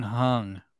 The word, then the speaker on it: Jung: I thought in Korean that the J was pronounced H, so it was Sung Hung.